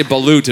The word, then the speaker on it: balut: I think it's called balut. Is it balut, called? Yeah. Balut. I think is how you say it.